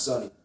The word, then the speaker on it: SunNY: I wrote my name on the sign up list and I spelled it S-U-N, capital N, period, Y, period, but really only mean it for it to be pronounced Sunny.